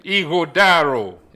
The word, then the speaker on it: Ighodaro: Erhabor is my first name. Ighodaro.